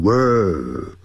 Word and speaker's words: World: 我们发觉下面有一个 title, T-I-T-L-E, 也就是我们讲的名称或者是一个头衔都叫做 title, 在里面就是叫做就是课题，也就是课文的这种标题，那叫做 World War II。世界那个字叫 World, 因为有一个 R 还有一个 L, 所以先念成卷舌音 World, 然后那个 L 呢翻成欧洲的 O, World, 就 World, 好，那个 D 念得很弱，好， World War IIあ、就是第二次世界大战。那么我们来看看今年的文章，我写的比较短。那么先来看一下。All of us know that World War II took place in Europe on September the 1st, 1939.